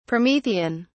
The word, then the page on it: promethean: British English